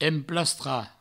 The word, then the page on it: emplastrar: emplastra